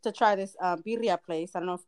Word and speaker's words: birria: It's birria.